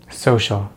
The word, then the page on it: social: American English